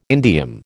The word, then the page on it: indium: American English